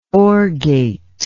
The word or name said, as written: orgate